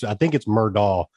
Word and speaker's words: Murdaugh: I think it's Murdaugh. The G is silent.